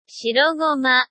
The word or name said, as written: shirogoma